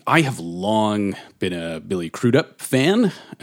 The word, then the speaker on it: Crudup: Billy Crudup.